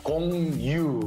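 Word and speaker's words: Gong Yoo: Con También la segunda sílaba you se pronuncia justo tú en inglés. Se pronuncia you, ¿verdad? Yu. Entonces, con, no, Kong Yu.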